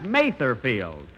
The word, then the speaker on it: Mather Field: Here it is, Mother's Day, and we're at Mother Field. – That's Mather Field. And although I pronounced it wrong last week, I mean, and speaking of Mother's Day...